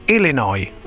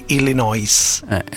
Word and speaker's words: Illinois: Ecco, ripetilo bene il primo, che l'Illinois non si legge la S finale, tutti sbagliano, invece si dice Illinois. – Illinois. – Non Illinois.